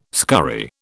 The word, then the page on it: scurry: British English